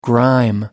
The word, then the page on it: grime: American English